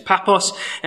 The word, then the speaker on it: Paphos: I called it another thing, and a guy in our church who was in the army really told me off for pronouncing the word wrong because he was stationed there. It's Paphos.